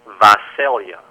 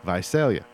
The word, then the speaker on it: Visalia: Visalia. – Visalia.